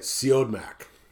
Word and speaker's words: Siodmak: How do you say his last name again? I say it Siodmak.